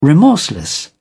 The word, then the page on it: remorseless: British English